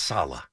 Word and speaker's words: Saleh: Can we get his name right? It's Saleh. I mean, let's at least get the guy's name right.